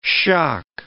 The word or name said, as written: shock